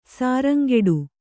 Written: ಶೃಂಗಾಟ

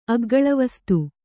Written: ಅಗ್ಗಳವಸ್ತು

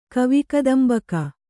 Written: ಕವಿಕದಂಬಕ

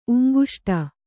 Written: ಉಂಗುಷ್ಟ

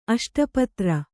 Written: ಅಷ್ಟಪತ್ರ

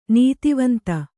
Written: ನೀತಿವಂತೆ

